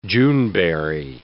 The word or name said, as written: Juneberry